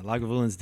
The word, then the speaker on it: Lagavulin: And it was the 16-year Lagavulin? Mm-hmm. Is that how you pronounce mm-hmm. – it? – That's right. Lagavulin.